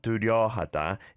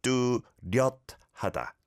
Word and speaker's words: 뚜렷하다: So it's not 뚜렷하다, it's 뚜렷하다. 뚜렷하다. 뚜렷하다. Give that some practice as well.